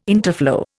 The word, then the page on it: interflow: Indian